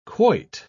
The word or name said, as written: quoit